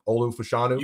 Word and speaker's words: Olu Fashanu: And Caleb Williams, Drake May, Jaden Daniels, Marvin Harrison Jr., or one of the top tackles, whether it's Joe Alt or I always can't pronounce his name, Olu Fashanu. – You got it. Right? Nailed it.